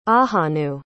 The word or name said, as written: ahanu